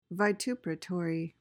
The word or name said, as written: vituperatory